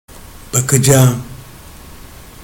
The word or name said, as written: bakacagim